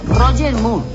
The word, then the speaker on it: Roger Moore: Me encontré con Jorge Merré, Jorge Morré, ¿cómo se dice? Jorge Morré. – ¿La conoces? – No, de nada. – Roger Moore. Roger Moore. El santo.